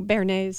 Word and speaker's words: béarnaise: Oh, béarnaise sauce.